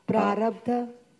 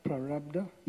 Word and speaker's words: prarabdha: How do you say it again? – Prarabdha. – Yeah, prarabdha.